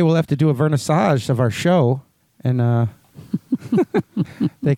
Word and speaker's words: vernissage: do a massage of the show. – Did I say it wrong? – No. – Vernissage.